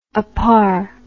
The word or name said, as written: apar